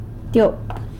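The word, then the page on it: tyop: San Lucas Quiaviní Zapotec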